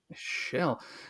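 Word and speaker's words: Kjell: Shell. Spelled with the KJ.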